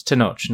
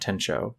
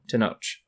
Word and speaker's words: Tenoch: I said his name wrong. It's Tenoch, not Tencho. Tenoch.